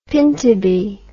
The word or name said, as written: pintubi